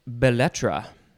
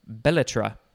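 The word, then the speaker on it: belles-lettres: I thought it was maybe a musical instrument, because sometimes they end in P-H-O-N, but not this one. Next is Belletra, or I don't think there's much of an emphasis on any specific syllable. Belletra. B-E-L-L-E-S is the first word. L-E-T-T-R-E-S is the second word.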